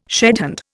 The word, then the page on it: shedhand: Indian